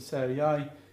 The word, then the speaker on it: Sarai: So it goes from Sarai, the Y sound at the end, to Sarah with the H sound at the end.